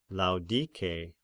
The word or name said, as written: Laodike